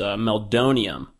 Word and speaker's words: meldonium: And I believe it's pronounced Meldonium.